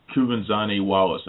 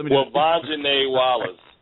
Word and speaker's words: Kuvenzhané Wallace: But yeah, I really want to see it one more time and get a final judgment on Kuvenzhané Wallace. I'm pretty sure I butchered her name, I think. – Let me do it. – Kuvenzhané Wallace.